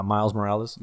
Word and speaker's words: Miles Morales: Yeah, Miles Morales.